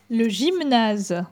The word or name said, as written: Le gymnase